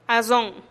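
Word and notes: azon: Teotitlán del Valle Zapotec

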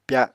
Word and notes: pya: Apatani

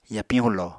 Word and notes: yapiñ hulo: Apatani

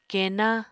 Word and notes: keená: Apatani

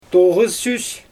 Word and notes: toɣɯssys: Xyzyl

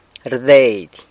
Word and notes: rdeidy: sanlucasquiavini

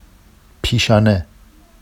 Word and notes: piʃɑne: Vafsi

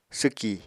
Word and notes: swki: apatani

